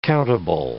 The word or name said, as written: countable